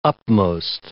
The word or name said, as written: upmost